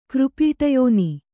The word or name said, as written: ಕೃಪೀಟಯೋನಿ